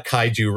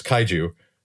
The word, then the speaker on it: kaiju: Anyway, many, many occasions where she has she wants to create costumes for the kids for various plays, a kaiju wrestling event, all sorts of things.